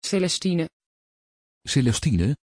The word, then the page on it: celestine: Dutch